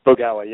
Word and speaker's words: Bogawa: Bogawa, yep, that's perfect.